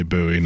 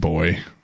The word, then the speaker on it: buoy: I say buoy, not boy.